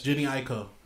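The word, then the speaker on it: Jhené Aiko: Yes, Jenny Aiko.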